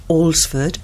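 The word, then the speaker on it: Alresford: Thanks to Dr. Peter Abraham for his help explaining why Allsford is pronounced Allsford, or as the 1960 Concise Oxford Dictionary of English Place Name states, Allsford.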